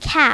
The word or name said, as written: cap